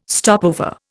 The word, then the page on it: stopover: Indian